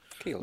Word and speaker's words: Kiehl's: It's only whenever I went to I, I never pronounce it, but is it Kiehl's? Kiehl's? Mm-hmm. Kiehl's? Kiehl's? – Kiehl's.